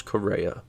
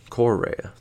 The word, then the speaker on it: Correa: Correa, if I'm not mistaken, is only like 25. Let's look it up. I'm gonna look it up right now. Carlos Correa. Um, I'm sorry, Correa. That's what it is.